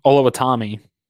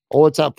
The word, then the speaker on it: Oluwatimi: Oluwatimi. Oluwatimi from Michigan, interior offensive lineman with the fourth round.